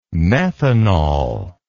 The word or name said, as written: methanal